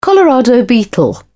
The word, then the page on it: colorado beetle: British English